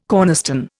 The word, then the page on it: cornerstone: Indian